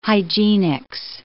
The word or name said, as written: hygienics